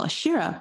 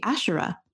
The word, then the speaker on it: Asherah: So I call Ashira, but it's actually Asherah.